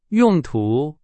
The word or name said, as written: 用途